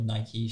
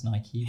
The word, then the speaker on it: Nike: So, for an example, Nike was paying her, or Nike, should I say, for Julia. – Thank you. – And also for all our international listeners, because it is pronounced Nike, because they are an American brand.